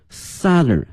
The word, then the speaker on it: southern: 杭州是它的这个最南端了，用了这个 end， E-N-D，同时前面用这个 southern 而不用这个 south 啊，用它的这个南部的南端的形容词，同时这个发音哈，大家已经注意到了，是 southern 小口型的 R 音，而不是这个 south 啊大口型的这个 R，而是这个 R 啊，所以说这个词从名词哈变成形容词呢，是一个变音的过程哈，这个 south 变成了 southern end of Beijing Hangzhou Grand Canal。